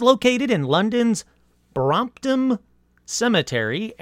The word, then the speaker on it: Brompton: So let me double check the Brompton part of Brompton Cemetery right now. Oh, no, I didn't say. I said it right.